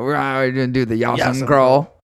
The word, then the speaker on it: Jas Sum: Is that how you say it? I know it's Yas. Yas, right? – Yasam. Yasam. – Kral. Yasam Kral.